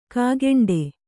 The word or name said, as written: ಕೈಗುಂಟೆ